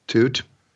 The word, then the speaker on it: Tuite: By the way, the Wall's real name, am I saying this right? Jerry Toot? – Yes. – T-U-I-T-E. Would you call that Toot? – Toot.